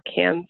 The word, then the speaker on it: Cairns: And then I fly from LAX to Sydney, which is 15 hours, and then from Sydney to Cairns, or Cairns is how they pronounce it in Australia, which is another few hours.